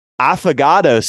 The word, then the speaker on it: affogato: To change things up, try it affogato style with a hot espresso shot poured right over the top. I don't know if I pronounced that was pretty good.